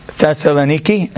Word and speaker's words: Thessaloniki: There was a town in Greece called Thessaloniki, if I'm pronouncing it right. Thessaloniki, that's what it is.